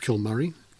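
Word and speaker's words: Cill Mhuire: I think it's pronounced Kilmurry. C-I-L-L, new word, M-H-U-I-R-E.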